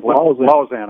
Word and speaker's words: Lausanne: Last year, Lausanne, no problem. I say that right? – Lausanne. – I always want to say Lausanne.